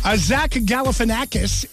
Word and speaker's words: Zach Galifianakis: Zach Galifianakis is 52 today. – It's good to pronounce it that way. Yes, I have a rough time with his name. I'm glad you took care of it.